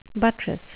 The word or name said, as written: buttress